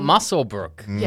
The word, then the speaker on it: Muswellbrook: Oh, and we are going into the Hunter era today to talk about Muswellbrook.